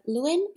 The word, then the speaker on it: Lewin: And you let us know in the book that you were influenced by the work of Lewin.